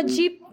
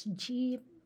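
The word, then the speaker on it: dyip: Wait, so jeep, which we spell it D-E-E-P, D-Y-I-P, jeep. We don't say J-E-E, because Jeep is a brand. Yeah. Okay.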